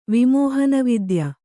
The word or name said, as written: ವಿಮೋಹನವಿದ್ಯ